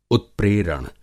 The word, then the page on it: उत्प्रेरण: masculine noun